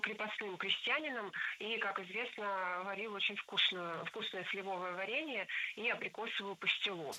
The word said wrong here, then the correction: сливовое: Сливовое варенье – это ошибка. Ударение в нашем прилагательном должно падать на первый слог.